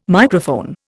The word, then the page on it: Microphone: Indian